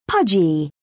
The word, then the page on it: pudgy: British English